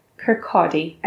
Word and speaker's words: Kirkcaldy: For our Australian listeners, that is spelt K-I-R-K-C-A-L-D-Y. Kirkcaldy.